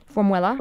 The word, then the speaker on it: Formwela: So all the songs in the album are titled, and I'm going to spell it, F-O-R-M-W-E-L-A, Formuela. – Formula. – Formuela. – Formula, yeah. – Like formula or formwell? – Like formula.